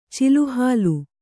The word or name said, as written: ಚಿಲುಹಾಲು